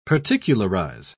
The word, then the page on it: particularise: British English